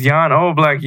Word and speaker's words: Jan Oblak: John Oblak. – It's Jan Oblak, you fucking dickhead.